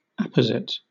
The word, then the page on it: apposite: British English